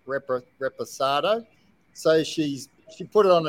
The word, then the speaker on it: Reposado: How do you say it? – Reposado. – Reposado. – Well done. Reposado.